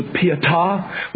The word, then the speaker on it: Pietà: Suppose the day Michelangelo finishes tremendous work of art that they've just had to repair, and I understand the proper way to pronounce it is the Pietà, with the emphasis on the last syllable.